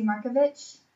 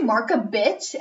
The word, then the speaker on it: Markovich: My last name is spelled M-A-R-K-O-V, like as in Victor. I have to say that on the phone because people are like, bitch. Lexi Marka bitch. And I'm like, oh my God, no.